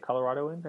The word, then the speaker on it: Coloradoan: Is that right how you say it? Coloradoan, I believe? – Yeah.